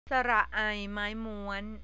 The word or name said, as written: aimaimuan